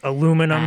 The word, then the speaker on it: aluminum: aluminium superconductors. – That's aluminum and... for us here in the States. Oh, yes. Sorry, sorry. I forgot that there's a... also people that uh, pronounce it incorrectly.